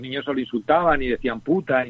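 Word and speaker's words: Butter: Y en alemán la mantequilla se, se pronuncia para que lo entendamos, muy parecido al inglés, se escribe muy parecido al inglés, pero la pronunciación es más cerrada y entonces más o menos queda como puta Y, aquí se entendió como puta.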